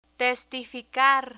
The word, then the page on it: testificar: spanish